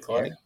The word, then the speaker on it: Kluck: So, your two friends, uh, Ted Kluck, am I saying his name right? That's right, Ted Kluck.